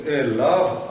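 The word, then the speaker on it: элав (אליו): Читаем «камац» и «вав». Йод не читаем. – Ясно. – То есть произносим «элав», «алав», «тахтав» и «ахарав». Йод остается только на письме.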